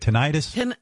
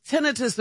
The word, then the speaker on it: tinnitus: tinnitus? – It's tinnitus or tinnitus. It's both ways.